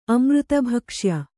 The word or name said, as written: ಅಮೃತಭಕ್ಷ್ಯ